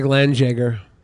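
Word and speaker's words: Landjäger: It's pronounced like Landjager. – Landjager.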